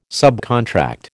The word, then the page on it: subcontract: American English